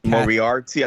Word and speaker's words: Moriarty: Do we think Kathy Moriarty, I always say her name wrong. – Moriarty, I think. That's how I've always said it.